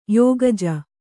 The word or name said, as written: ಯೋಗಜ